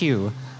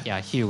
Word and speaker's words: Hioe: But the Hakka pronunciation is Hu, but because, you know, it should be H-I-U, but because of the Dutch influence, U becomes O-E.